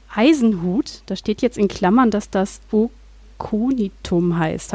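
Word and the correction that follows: Aconitum: Habe ich das richtig ausgesprochen? – Akonitum. – Ah, ja, genau.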